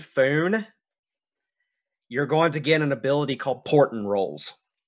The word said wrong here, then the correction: Portent: Can you tell me how to pronounce that one? – Portent. – Portent. – P-O-R-T-E-N-T. – Portent. Yep. Portent.